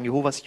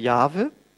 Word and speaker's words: Jahwe: Darauf, das, daraus haben die Zeugen Jehovas Jehova gemacht, ja, weil der Name im Hebräischen keine Vokale hat. Äh, aber die Bedeutung äh, oder die Aussprache richtigerweise ist Jahwe.